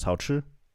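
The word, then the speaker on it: Haochi (好吃): So would you say this one is haochi? – How do you say it? Haochi? – Hao, like ni hao, hao. – Hao. – And then chi.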